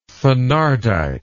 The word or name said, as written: thenardite